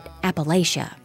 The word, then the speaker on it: Appalachia: He pronounces it Appalachia, by the way. Listeners of our show know that I pronounce it like Vance's Kentucky family says it. Appalachia, like throw an apple at ya.